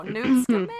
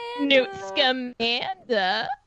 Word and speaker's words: Newt Scamander: Oh, Newt Scamander. – Newt Scamander.